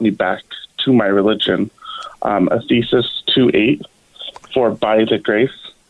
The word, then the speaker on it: Ephesians: That's Ephesians. It's pronounced Ephesians.